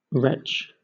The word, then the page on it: retch: British English